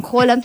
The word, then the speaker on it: Haaland: Y estoy hablando, obviamente, de Haaland, o también Holland, porque se supone que la doble A se pronuncia como O.